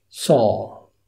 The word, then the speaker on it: saw: So so، إنما دي مش so، دي saw saw. سمع الفرق؟ التلاتة دول so، إنما دي saw so saw so saw. طيب ده الأمريكاني، الفرق واضح جدا so saw.